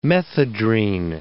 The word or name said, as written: methedrine